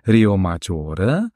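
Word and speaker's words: Riomaggiore: Also es sind ganz konkret das Dorf Rio Maggiore, dann haben wir noch Manarola, Cornelia, Vernazza und noch Monterosso al Mare. Habe ich das richtig ausgesprochen? – Esatto, sì, no, le pronunciati bene.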